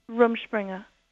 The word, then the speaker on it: Rumspringa: Rumspringa.